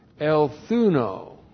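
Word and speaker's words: euthuno: Elthutes comes from the verb elthuno. E-U-T-H-U-N, long O. Elthuno.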